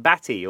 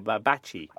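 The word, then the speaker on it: BATE: Is it Bati or Bachi? – I have no idea how they say it. I can tell you how the Spanish are saying it. – How are they saying it? – Well, they're calling it Bati, yeah.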